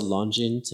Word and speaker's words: Longines: So the answer to what we sold and we sold Longines too.